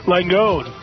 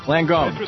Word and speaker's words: Langone: Am I saying that right, Dr. Kaplan? – Langone. – Langone.